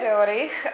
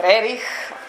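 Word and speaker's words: Roerich: A právě Rerich, nebo Rerich, jako jsou dvě možnosti vyslovnosti jeho jména, a byl inspiratorem založení v roce 1923 skupiny, takzvané skupiny malířů kosmistů, která se jmenuje Amaravela.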